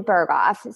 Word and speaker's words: Berghoff: Well, my name's Maggie Berghoff. So um, Berghoff is spelled B E R G H O F F.